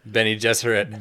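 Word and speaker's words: Bene Gesserit: I don't even know, like all the different Beth Gesserit or whatever. – Benny Gesserit. – Benny Gesserit. Benny Gesserit.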